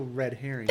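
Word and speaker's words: Read Herring: How would you pronounce that? R.E.A.D. herring. It's a bookstore. Has a fish on it. – I mean, I'd probably say still red herring. – Thank you. – Yeah, I say reed herring.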